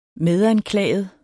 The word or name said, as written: medanklaget